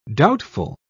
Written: doubtful

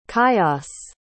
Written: chios

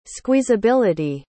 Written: squeezability

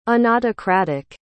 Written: unautocratic

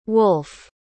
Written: Wolfe

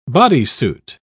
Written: bodysuit

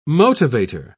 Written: motivator

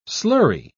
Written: slurry

